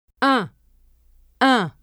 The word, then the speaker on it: un: Un is made of only one sound, the nasal vowel un, while une has two sounds, the vowel u as in tu and the consonant n. Let the air go through both your mouth and your nose when you pronounce un. Don't pronounce the letter n.